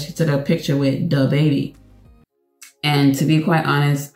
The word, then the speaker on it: DaBaby: It's not the baby or the baby. Is duh baby, duh baby.